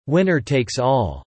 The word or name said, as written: winner takes all